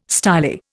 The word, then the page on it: styli: Indian